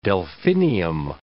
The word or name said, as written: delphinium